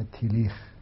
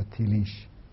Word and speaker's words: تیلیش: یک معنای خاصی که در غرب رایج شده و شخصیت برجسته او هم فردی به نام تیلیخ یا تیلیش که تلفظهای مختلفی داره. این گاهی تیلیخ گفته میشه، گاهی تیلیش تلفظ میشه، گاهی تیلیک هم حتی گم میگن.